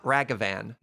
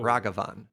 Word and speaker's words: Ragavan: It's not Ragavan. No. Ragavan. – Please ignore my ears on this one. But as long as you're not calling it Ragavan, you're going you're probably to probably, fine.